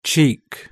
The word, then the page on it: cheek: British English